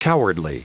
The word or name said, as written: cowardly